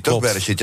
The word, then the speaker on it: Bereshit: En zo klonk het ongeveer 8 uur geleden op Cape Canaveral in Florida, de lancering van de Israëlische maanverkenner Bereshit. Zeg ik het goed? Is het Bereshit? – Bereshit.